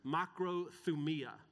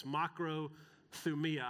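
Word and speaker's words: makrothumia (μακροθυμία): And the word that Paul uses for patience is the Greek word makrothumia. And I don't often share the Greek word, but this word is too much fun to pronounce, makrothumia.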